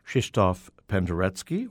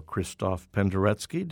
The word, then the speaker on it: Krzysztof Penderecki: All of the selections that we heard today were conducted by Krzysztof Penderecki, or Krzysztof Penderecki, depending upon if you want to use the Polish or international pronunciation. Penderecki, by the way, is spelled P-E-N-D-E-R-E-C-K-I, Penderecki.